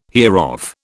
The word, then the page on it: hereof: British English